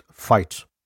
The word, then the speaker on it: White: Lily married a local man named Andrew White, in the local dialect pronounced Fight,